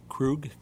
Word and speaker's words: Krug: Krug, K-R-U-G.